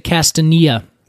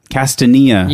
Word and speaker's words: Castanea: As you have alluded to already, today we are talking about the American chestnut Castanea dentata. – I love how you say it. I would say Castanea. – Castanea.